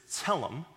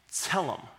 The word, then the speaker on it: tselem: The Hebrew word there is tselem, kind of a T-S, tsa sound, E-L-E-M, with the accent on the first syllable. So if you want to repeat after me, okay, tselem. Good, good, okay.